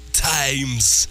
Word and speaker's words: Thames: Is it River Thames? – I think so. – It's spelled T-H-A-M-E-S. – No, yeah, I know. Times, less a river.